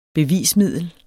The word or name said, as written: BEVISMIDLETS